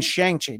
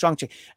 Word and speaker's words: Shang-Chi: It's like, yeah, you mean Shang-Chi. No, actually it's pronounced Shang-Chi.